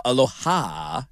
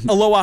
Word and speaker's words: Aloha: People are saying, can we get Thomas Tyner on the line for Aloha Aloha? And unanimously supporting of Aloha. – The emphasis on the ha.